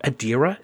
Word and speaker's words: Adira: Blue Del Barrio will play Adira. I'm thinking that's how you're going to pronounce it. I'm going to go with that. Adira. It sounds good. – It is.